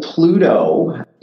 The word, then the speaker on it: Plooto: So Pluto is a really neat product. It's spelled P-L-O-O-T-O.